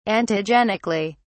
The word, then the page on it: antigenically: British English